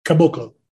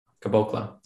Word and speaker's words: Caboclo: The Celtics, since we last talked, signed Bruno Capogolo. I hope I said that right. Probably not. – No, that's what you call Capogolo. You're doing a Sopranos impersonation. It's Caboclo. – Caboclo.